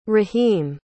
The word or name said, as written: raheem